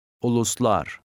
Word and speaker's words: Uluslar: Put the stress on the last syllable. – Uluslar.